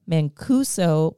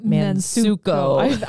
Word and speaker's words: Mancuso: And then our director is, oh my gosh, we love this name, but we say it wrong every time. Gail Mancuso, but we want to call her Mansuko.